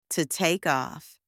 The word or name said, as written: to take off